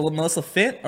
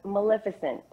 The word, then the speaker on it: Maleficent: Is it Melissa Fint or Melissa Maleficent? – Was it Melissa Fint? – Maleficent. – Well, you nailed it, right? – Yeah.